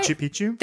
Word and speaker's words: Machu Picchu: Machu Picchu? – Thank you.